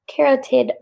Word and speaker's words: carotid: It's one of those words that looks weird when you read it. – Yeah. Carotid. There you go. – Carotid. – That's how you say it.